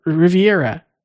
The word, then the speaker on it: Riviera: that they had the ACB convention at the Riviera. I hope I'm pronouncing that correctly. – You are correct.